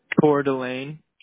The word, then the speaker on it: Coeur d'Alene: It's like a, like C-O-U-E-R. – How do you pronounce it? – Coeur d'Alene. – Is it C-O-U-R, C-O-E-U-R, D, and then A-L-E-N-E? – Yeah.